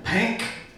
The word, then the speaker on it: Pank: Over 75 publications include Shivers, Volume 6, Cemetery Dance with Stephen King and Peter Straub, Pank, did I pronounce that correctly? – Yeah. – Pank. Extra emphasis on the K is written here.